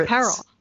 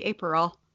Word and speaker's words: Aperol: Apparel. I'm not wearing it, so it must be Aperol.